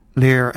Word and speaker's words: Lehr: And the second version of the Southern Cross was collected by Lear and Best in 1976. I'm pronouncing the name Lear. It's spelled L-E-H-R.